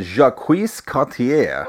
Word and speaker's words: Jacques Cartier: Jacques Cartier.